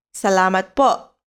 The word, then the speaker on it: salamat po: In Filipino, the formal way of expressing gratitude, example thank you is salamat po. Salamat po. Let's break it down by syllable. Sa, la, mat, po.